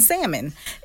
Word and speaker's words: salmon: You are not asking too much, for example, to expect a man not to pronounce the L in salmon. If that bothers you, you have the right to like what you like, sis.